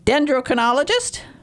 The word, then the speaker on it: dendrochronologist: dendrochronologist? – Yes, yeah, absolutely right.